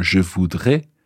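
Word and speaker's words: je voudrai: Notice the pronunciation there, je voudrais, A-I at the end, as opposed to je voudrais, A-I-S.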